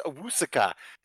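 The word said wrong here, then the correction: Owusu-Koromoa: There's a hyphen in there, and you went past the hyphen. – Right. – And you put the K from the second half of the hyphen, and you moved it over to the front half of the hyphen. It's amazing that you could do that. You have a talent. – Yeah, your mind is special. – I think it's pronounced Owusu... Koromoa.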